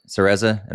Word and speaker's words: Cereza: Is it Cereza? – Yeah, Cereza. – Cereza.